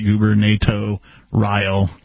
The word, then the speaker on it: gubernatorial: There's then, an R in there, though, that doesn't seem to get pronounced. – There is. It's Guber, G-U-B-E-R-N-A-T-O-R-I-A-L, right? Guber, NATO, Ryle.